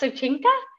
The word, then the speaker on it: Soyinka: I'm so excited to talk about a play called Death and the King's Horsemen uh, by Wole Soyinka so I think it's pronounced Sochinka.